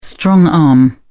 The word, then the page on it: strong arm: British English